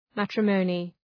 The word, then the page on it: matrimony: ‘mætrə,məʋnı